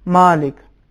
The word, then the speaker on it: مالک: یا کلمہ مالک جو ہم نماز میں بھی پڑھتے ہیں سورہ حمد میں, مالک یوم الدین, میم پھر الف ہے چونکہ میم حروف ترقیق میں سے ہیں لہذا اس کا تلفظ اس طریقے سے صحیح نہیں ہے. مالک صحیح تلفظ یہ ہے, مالک یوم الدین.